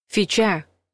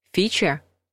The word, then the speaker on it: фича: Фича — неправильный вариант. Фича — правильный вариант.